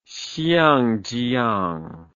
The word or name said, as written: xiangjiang